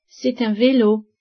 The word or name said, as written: velo